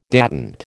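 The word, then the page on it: détente: American English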